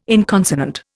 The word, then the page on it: inconsonant: Indian